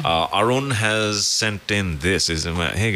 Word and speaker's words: Arun: It's A-Run. – Yeah. – That's how A-R-U-N is Aaron. – That's how you say his name. That's how he says his name.